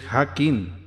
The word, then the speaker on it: Jakin: Esa palabra de poder o mantra es Hakin. Se escribe J-A-K-I-N. Sin embargo, se pronuncia en vez de J como una Y. O I sola.